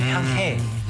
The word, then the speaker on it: 향해: 네, 그 히읗 발음이 좀 세거든요. 향해.